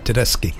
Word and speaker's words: Tedeschi: Oh, I could say her name properly. Yeah, got it right, Tedeschi.